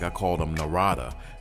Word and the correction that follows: Narada: He said, no, it's Narada, like Florida.